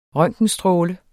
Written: røntgenstråle

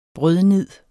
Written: brødnid

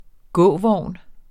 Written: gåvogn

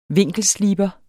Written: vinkelsliber

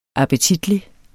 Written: appetitlig

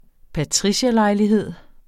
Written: patricierlejlighed

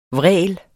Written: vræl

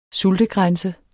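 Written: SULTEGRÆNSE

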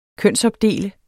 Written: kønsopdele

